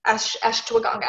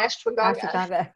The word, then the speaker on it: Ashwagandha: Ashwagandha. I always say that wrong. Ashwagandha. Thank you.